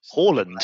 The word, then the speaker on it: Haaland: It is in England, but if you actually pronounce his name properly, it's Haaland. – Haaland sounds like the sort of place that Kingo goes on his holidays.